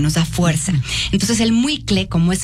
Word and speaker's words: muicle: Se llama muicle, se escribe M-U-I-C-L-E, muicle.